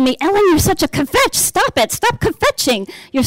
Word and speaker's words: kvetch: And we pronounce it kvetch or kvetch.